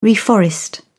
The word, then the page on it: reforest: British English